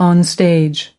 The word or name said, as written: onstage